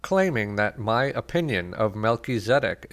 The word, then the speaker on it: Melchizedek: The three priesthoods are of a patriarchal order, the Levitical order, and the order of Melchizedek, which is pronounced Melchizedek.